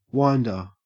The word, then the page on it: winder: Australian English